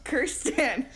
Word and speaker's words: Kirsten: It's like Kristen's... What's her name? Dunst all over again. – Kirsten. – I feel like it's not spelled like that. – It is. I looked it up and, like, double-checked. – Okay. – It's definitely Kirsten. – It is. I'm wrong. Kirsten Dunst. Thank you.